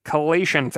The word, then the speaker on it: chelation: But for the record, C-H-E-L is pronounced like key when you're saying chelation therapy, which is C-H-E-L-A-T-I-O-N.